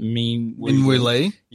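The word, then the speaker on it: Min Woo Lee: Is it Minwoo Lee? – Yeah.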